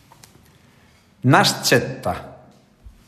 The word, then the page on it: Nascetta: nas-cetta